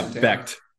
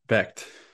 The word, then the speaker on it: Becht: And Rocco, is it Beck? I don't know. You're the pronunciation. – Yeah, Becht. Becht.